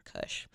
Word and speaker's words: Kusche: Larry K-U-S-C-H-E. – Oh. Okay. Which you pronounce... – Kush. Larry Dank Kush.